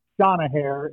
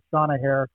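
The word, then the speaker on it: Seanathair: It's Seanahair is how you pronounce it. I posted a couple of photos of it a week or so ago, Seanahair.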